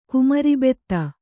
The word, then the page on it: ಕುಮೆರಿಬೆತ್ತ: kumeribetta